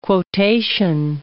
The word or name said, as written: quotation